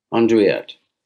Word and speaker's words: Andouillette: But what is the difference between that and, please help me with my pronunciation, is it Andouillette? – Andouillette. – Oh, that's much better.